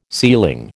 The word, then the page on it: ceiling: American English